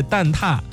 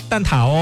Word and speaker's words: 蛋挞: 正确的读音是蛋挞，不读蛋挞哦。